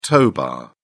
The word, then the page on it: tow bar: British English